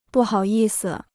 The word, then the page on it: 不好意思: bù hǎo yì si